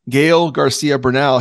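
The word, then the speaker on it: Gael García Bernal: It is Gael Garcia Bernal.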